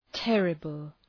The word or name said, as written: terrible